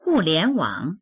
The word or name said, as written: hu4lian2wang3